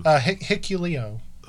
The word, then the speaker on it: Hikuleo: How do you say Hikulu? Hikulu? – Hikuleo. – Hikuleo. Now, Hikuleo.